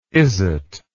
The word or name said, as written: izzat